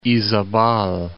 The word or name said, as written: Izabal, Lake